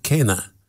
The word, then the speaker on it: quena: Q is for the Q-U-E-N-A, pronounced Kenna.